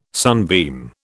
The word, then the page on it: sunbeam: British English